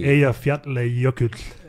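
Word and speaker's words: Eyjafjallajökull: Eja Fjatle Jokutl. – Tako nešto, a? – Islandski, pritom sam sad potrudio se da prilično pravilno islandski izgovorim, da.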